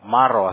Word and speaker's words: Mauro: Y para tu primer nombre, nomás para tenerlo correcto, se escribe M-A-U-R-O, Mauro, ¿así va? – Mauro. – Mauro. M-A-U-R-O, así en inglés, ¿ah? – Sí.